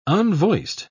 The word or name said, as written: unvoiced